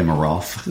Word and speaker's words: Nemiroff: But Nemiroff.